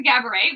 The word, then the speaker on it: Gabourey: So back to Gaboray, which her name is spelled G-A-B-O-U-T. Curey. And she's like, it's pronounced like cabaret.